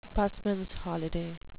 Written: busmans holiday

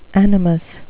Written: animus

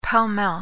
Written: pell-mell

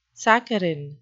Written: saccharine

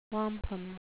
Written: wampum

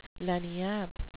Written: lagniappe

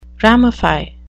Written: ramify

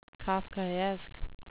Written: kafkaesque

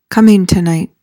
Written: cummingtonite

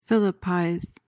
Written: philippize

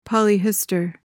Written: polyhistor

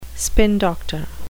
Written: spin doctor

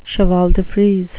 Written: cheval-de-frise

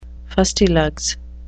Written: fustilugs

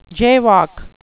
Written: jaywalk

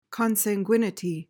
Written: consanguinity

